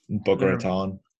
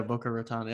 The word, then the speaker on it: Boca Raton: You meet up in Boca Raton or whatever. Boca. I don't know how to say it. – Boca Raton. – Yeah, Boca Raton.